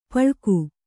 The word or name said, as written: ಪಳಕು